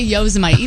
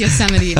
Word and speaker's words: Yosemite: How do you pronounce Yosemite? – Yosemite. Anytime he's making fun of me because anytime I read it, I say Yosemite, even though I know it's Yosemite.